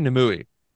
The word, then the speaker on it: Nimue: Did he say Nimue or Nimue? – They say Nimue. Because in the audio books, they say Nimue. The guy who read the book pronounces it Nimue. – Yeah. Yeah. – Yeah. No, so Nimue in the show.